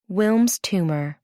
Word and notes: wilms tumour: American English

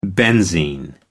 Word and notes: benzene: American English

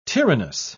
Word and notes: tyrannous: British English

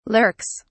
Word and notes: lurks: British English

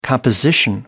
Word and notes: composition: American English

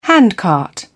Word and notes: handcart: British English

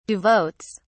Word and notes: devotes: British English

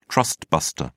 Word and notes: trustbuster: British English

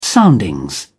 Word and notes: soundings: British English

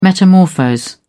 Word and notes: metamorphose: British English

